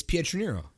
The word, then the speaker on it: Pietroniro: I know what your last name is, but I heard you say it, and when you said it, it did not sound like it's spelled at all. – Well, how do you think it's Pietroniro? – That's not how it sounded on your voicemail. – Dude, that's exactly how it is. I don't think I say my name differently on a regular basis.